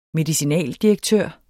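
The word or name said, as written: MEDICINALDIREKTØR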